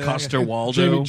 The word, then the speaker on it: Coster-Waldau: Once the internet went aflame when the poster for this movie came out, it was Gods of Egypt had Nikolai Coster-Waldau, whatever that, how do you say Coster-Waldau? – Jamie Lannister.